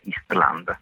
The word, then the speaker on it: Island: E l'album si intitola, dillo tu, Eastland, è scritto Island ma si pronuncia in islandese Eastland.